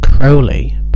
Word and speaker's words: Crowley: The track itself, though, is about the Satanist Aleister Crowley, which his name is pronounced Crowley, but Ozzy Osbourne mispronounces the name.